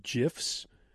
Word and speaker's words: GIF: He does these things with GIFs where like just one little thing in an image is moving. – Oh, you're a soft G, huh? – Yeah, sorry.